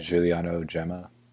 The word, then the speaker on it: Giuliano Gemma: I don't know how to pronounce it, but Giuliano Gemma.